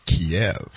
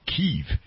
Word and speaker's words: Kyiv: And this morning, Jared and I spent some time learning how to pronounce Kyiv, which is the capital of the Ukraine, vice Kiev, which is the Russian pronunciation, and Kyiv is the Ukrainian pronunciation.